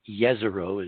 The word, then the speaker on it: Jezero: This is now an MRO, Mars Reconnaissance Orbiter, look-down shot of the landing site in Jezero Crater, or Jezero, as Will said is the appropriate pronunciation last week.